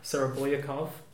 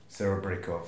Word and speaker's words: Serebryakov: For instance, Alexei Serebryakov. – Serebryakov. – Serebryakov, oh, so close with the pronunciation.